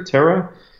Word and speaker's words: Terra: Now, in hindsight, the piece that I'm the most like, so the third poster in the series, the Terra poster, Terra, you pronounce two R's as H in Portuguese.